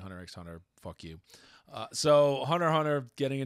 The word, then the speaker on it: Hunter x Hunter: Hunter x Hunter is getting, Hunter, sorry, you're not supposed to pronounce the X, people get mad, is getting a new volume.